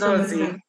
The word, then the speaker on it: Ngozi: Oh, Ngozi. I've read Ngozi.